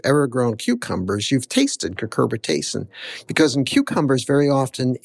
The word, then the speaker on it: cucurbitacin: There's a compound, it's a natural compound that all squash have, cucumbers have it as well, called cucurbitacin.